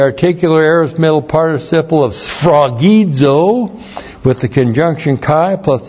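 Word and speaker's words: sphragizo (σφραγίζω): S P-H-R-A-G-I-Z, long O, sphragizo.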